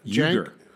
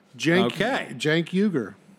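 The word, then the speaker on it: Uygur: Uger. Uger, like Y-O-U-G-E-R-R-R-R-R-R-R. Jenk Uger.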